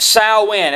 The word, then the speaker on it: Samhain: Okay, it's spelt Samhain, but it is pronounced Samhain. It's spelt Samhain, but it is pronounced Samhain.